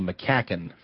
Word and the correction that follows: McMacken: Forgive me, McMacken. McMacken is his name.